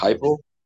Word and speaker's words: Heupel: I think it's Heupel.